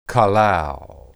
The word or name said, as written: qalal